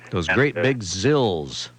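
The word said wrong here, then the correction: ZiL: Is it Zills? – That's what we're told. It might be pronounced Zeal. What is it? Zeal. Evelyn tells us it's a Zeal.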